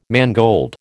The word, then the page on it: mangold: American English